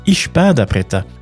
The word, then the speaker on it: espada preta: Ça s'écrit espada preta, mais on prononce espada preta, accompagné d'une banane frite et du milho frito.